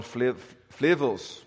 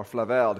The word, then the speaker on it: Flavel: And I'm reminded often of John Flavel's, or Flavel, depending how you pronounce it, the Puritan, who said, God's providence is like Hebrew, it's best read backwards.